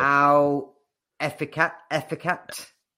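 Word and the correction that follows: Al-Ettifaq: Al Etifak. Etifak.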